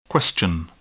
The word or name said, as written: question